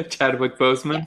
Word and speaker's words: Chadwick Boseman: Chadwick Boseman.